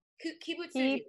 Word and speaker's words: Kibutsuji: Is it Kibutsuji? Is that how you say it properly? – I think we were saying Kibutsuji. – Kibutsuji. Okay. I like how it sounds better.